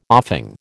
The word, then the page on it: offing: American English